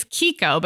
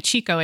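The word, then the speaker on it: Chicco: It's Kiko, but if you've been saying Chico in your head like me, don't feel bad.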